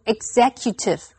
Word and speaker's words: executive: 这个计划执行的时间比我们原先所预测的还要久。和它相关的名词还有 executive，注意它的重音是在 XE 这个音节，所以念成 executive。